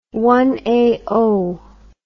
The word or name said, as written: one another